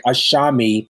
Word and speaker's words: ASAMI: And I should say it's spelled ASAMI, but it sounds like there's an H there between the with the S. So it's like ASAMI Teas.